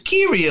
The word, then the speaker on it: Kyrie: Anybody anyway, out there who's listening who's Catholic, you know it's Kiri eleison. It's Kyrie.